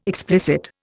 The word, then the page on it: explicit: Indian